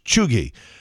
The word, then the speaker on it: cheugy: C-H-E-U-G-Y, pronounced Chuggy.